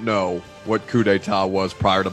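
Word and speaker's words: coup d'état: Now, did Mike Leach make you Google the definition of coup d'etat? I did pronounce that right, didn't I? – You did.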